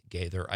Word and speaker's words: Gaither: Miss Amy Gaither. I hope I'm saying that right. Amy, welcome to Nightlight. – Hello. Hi, Pete. Yes, you are saying it right.